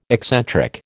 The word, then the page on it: eccentric: American English